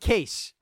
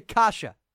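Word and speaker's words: Kaše: Not Case, or Kase. Andre Kasha. I believe I'm saying that right. I'm trying to tell you how to pronounce it. Imagine I'm getting it wrong. No, it's Andre Kasha.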